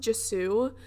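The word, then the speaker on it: Gisou: There's also one from, I believe it's pronounced Jisou.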